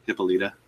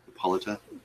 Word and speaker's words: Hippolyta: Why is I don't know how you pronounce her name. Is it Hippolyta? What was her mom's name? – Hippolyta. – Mm-hmm. Played by Connie Nelson. – Hippolyta.